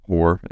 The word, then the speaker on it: Hoar: Hoar Construction, it's pronounced Hoar, and it is spelled H-O-A-R.